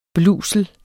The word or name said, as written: BLUSELENS